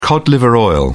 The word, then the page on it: cod liver oil: British English